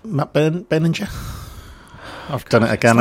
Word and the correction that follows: Berninger: It's like silent G. – It's like Joel's here. It's a hard G. – Hard G. Matt Berninger.